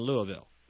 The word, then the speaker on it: Louisville: I said it right, didn't I? Louisville? Is that the way you guys say Louisville. – It? Okay. – We'll say it here.